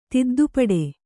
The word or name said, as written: ತಿದ್ದುಪಡಿ